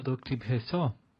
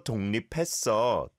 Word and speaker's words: 독립했어: 드디어 독립했어. – I can hear you pronouncing the kiok there on tok, but you don't need to, right? 드디어 독립했어.